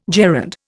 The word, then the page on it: gerund: Indian